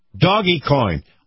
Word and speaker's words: Dogecoin: D-O-G-E-C-O-I-N. Is it Dogecoin? Coin? – Actually, Doggy. – Doggy coin.